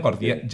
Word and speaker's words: Gordiano: lleva al nudo Jordiano, ¿lo he dicho bien? Gordiano. – Gordiano, sí.